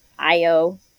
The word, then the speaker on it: Ayo: It's Ayo. It's spelled A-Y-O. So I was like, is that A-O or is that I-O? It's Ayo Adebiri.